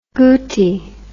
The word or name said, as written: Guti